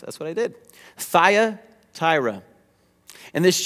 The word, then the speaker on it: Thyatira: But to this church, there's a church in Thyatira that we're going to be talking about today, which is a fun word to say, Thyatira. I looked it up on Google to make sure I was pronouncing it correctly.